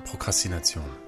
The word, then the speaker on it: Prokrastination: Prokrastination.